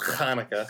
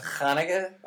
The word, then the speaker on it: Hanukkah: You celebrate Chanukah. Is that how it's pronounced? well, if, if, you want to get real technical with this, Hanukkah. Hanukkah. Um, but yeah, actually, uh, I, I, I celebrate both Christmas and Hanukkah.